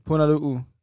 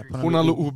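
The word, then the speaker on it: Punalu'u (Punaluʻu): Punalu. – Punalu? – Punalu Beach. – We're probably butchering that, but we'll take it. – Punalu'u. – Punalu'u? – Yeah, Punalu'u Beach.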